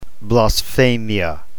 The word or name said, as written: blasphemia